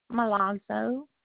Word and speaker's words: Milazzo: Milazzo. – Spell that for me. – M-I-L-A-Z-Z-O.